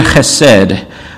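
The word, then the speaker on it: chesed (חֶסֶד): we would find the Hebrew word chesed. You can think of it as H-E-S-E-D to spell it in a way that would make sense to an English speaker. But I have trusted in your chesed.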